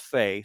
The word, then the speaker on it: Fi: Her name is spelled F-I, but it's pronounced Faye.